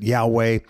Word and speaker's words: Yahweh: The Old Testament, he revealed his name, and we pronounce it Yahweh.